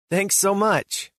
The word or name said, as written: Thanks so much